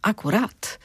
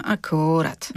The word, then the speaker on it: akurat: Uważność to też właściwe stawianie akcentów i tak jest właśnie w słowie akurat. Że kiedy ty mówisz, kiedy mówimy akurat, to wiemy, że chodzi o coś, co jest w sam raz, ale kiedy powiemy akurat, to jest takie powątpiewanie.